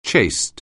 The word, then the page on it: chaste: anglais